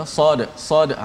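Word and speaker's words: sad sad (صاد): baris dan sebagainya so nama sad tu apa ejaan sad macam mana eh, sad alif dal maka kita kena baca sad uh, ah, di hujung qalqalah tetap bunyi macam biasa walaupun rupa dal tak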